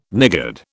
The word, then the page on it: niggard: British English